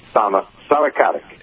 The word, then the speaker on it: Stana Katic: Stana Katic.